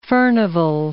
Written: Furnivall

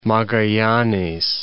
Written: Magallanes

